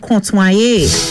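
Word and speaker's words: kontwayé: Your bonus Creole word for today is the word contoyer. Contoyer. And it is spelled K-O-N-T-W-A-Y-E.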